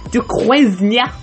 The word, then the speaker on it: Duquesne: Swordman is Jacques Du, du I can never remember to pronounce his name Duquesne, and uh, from Hawkeye in the previous episode.